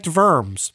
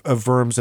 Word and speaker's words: Worms: When she said long O, is that more like Vorms or... – Oh, I guess. Yeah, that could be Worms.